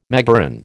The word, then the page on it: megaron: American English